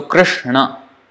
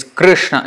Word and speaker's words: Krishna: So, is Krishna, it's not Krishna or Krishna, Krishna, R, R, not Ri, not Ru, not Ra, R, Krishna, Krishna. So, that's the actual pronunciation of the word Krishna.